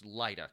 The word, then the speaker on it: Lyta: I felt that way when, I'm going to get her name wrong, Lyta. Lita? – It's Lyta, because it's short for Hippolyta.